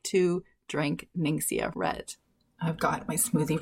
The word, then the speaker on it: Ningxia: She's a homeschool mama that engages in young living essential oils, and she sent me a package of something called Ninxia Red. Have you heard of it? I don't know that I'm pronouncing that right. It is Ningxia.